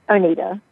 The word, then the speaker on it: Oneida: Our expert, Candice Oneida, or is it Oneida or Wine? Do you mind telling me your last name? – Oneida. – Oneida. Thank you.